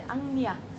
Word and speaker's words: Ajna: אנחנו, מכיוון שגילינו את המקום המודע, ואנחנו מגלים שזה אנחנו, אנחנו מגיעים למקום שנקרא אניה צ'קרה, רושמים את זה אג'נה, A-J-N-A, אבל מבטאים את זה אניה.